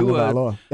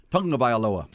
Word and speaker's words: Tagovailoa: And again, when you say Tua's name, people get mad. It's like, well, where are you getting this N? Tua Tagovailoa. But when you hear Tua. – Tagovailoa. That's what he says. – That's what Tua says. When Tua says, here's how you pronounce my last name, there's a tongue. It's like tongue.